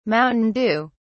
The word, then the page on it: mountain dew: British English